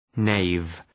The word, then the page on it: knave: neıv